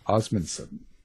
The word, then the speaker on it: Osmundson: Well, joining us, the other voice we got is Joseph Osmundson.